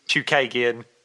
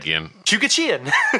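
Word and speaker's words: Chookagian: The correct pronunciation is Chukagian. And Stu said, Chukachian.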